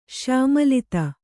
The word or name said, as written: ಶ್ಯಾಮಲಿತ